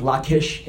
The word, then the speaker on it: Lachish: It's Lachish, but I think it's pronounced Lachish in Hebrew.